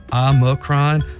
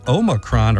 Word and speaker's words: Omicron: Officially, almost anything worked. Both Omicron and Omicron are fine. The Oxford Dictionary includes Omicron, and in classical Greek, it was pronounced with the second syllable sounding like me.